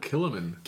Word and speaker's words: Kilchoman: Kilchoman. The C is silent. – Kilchoman. – Kilchoman.